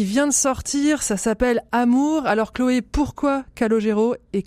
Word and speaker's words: A.M.O.U.R: Et euh, donc cet album, Amour, alors ça se prononce A-M-O-U-R, en fait il y a un point entre chaque lettre, parce qu'il le dit, euh, l'amour c'est un sujet euh, compliqué, en tout cas parfois ponctué.